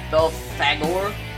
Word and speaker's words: Belphegor: Belphegor? B-E-L-P-H-E-G-O-R. Is literally how it is pronounced. – You went too fast. – Sorry. B-E-L-P-H-E-G-O-R.